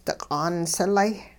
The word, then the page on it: diq'aan silay: hupa